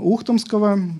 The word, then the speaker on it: Ухтомского: Ну и очень яркими представляются мысли епископа Андрея Ухтомского. Специалисты говорят, что правильно так стоит ударение.